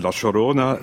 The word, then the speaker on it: La Llorona: Alors, ça s'écrit La Llorona, L-O-R-O-N-A, et maintenant que je l'ai vu, je sais qu'il faut dire La Llorona. Donc, voilà, c'est La Llorona, donc de Bustamante.